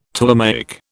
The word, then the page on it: Ptolemaic: British English